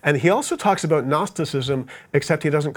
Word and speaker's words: Gnosticism: And then another religion began to spring up around the time of the New Testament called Gnosticism, spelled with a G, Gnosticism. And it was another religion, sort of a secret knowledge religion. The Greek word gnosis means knowledge, and so that's the root word of Gnosticism. We just don't pronounce the G in English anymore.